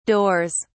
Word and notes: doors: British English

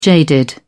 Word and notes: jaded: British English

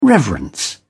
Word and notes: reverence: British English

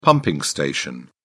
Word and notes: pumping station: British English

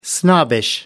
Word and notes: snobbish: American English

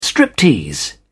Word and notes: striptease: British English

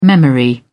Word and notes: memory: British English

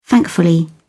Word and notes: thankfully: British English

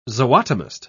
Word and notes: zootomist: British English

